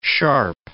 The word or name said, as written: Sharpe